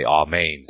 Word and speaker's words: amen: Well, amen to that, or I should say amen to that, if we're going to pronounce it the Hebrew way.